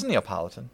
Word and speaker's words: Neapolitan: If you want to be fancy with it, you can say Neapolitan. – Is right? Neapolitan the correct pronunciation? Have we just been saying it wrong the whole time? – It's Neapolitan. Neapolitan.